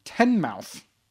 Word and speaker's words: Teignmouth: They are from Teignmouth, okay. But it's spelled Teignmouth.